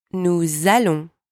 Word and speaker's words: nous allons: Make sure you use the liaison properly in the plural forms. It's nous allons, not nous allons. You've got to link the two words together with the Z sound.